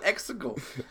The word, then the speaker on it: Exegol: Why do you call it, gotta call it Exegol? – Rhymes with testicle.